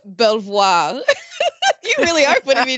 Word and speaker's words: Belvoir: So it's spelled B-E-L-V-O-I-R. And voir means to see. So, and bel means like pretty. So something pretty to look at. I don't know. But Belvoir.